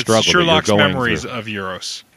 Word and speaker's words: Euros: and it turns out that Eros, did I say it right that time? – Euros. – Sister. – Euros. – Like Europe. – Okay, Europe, I got it.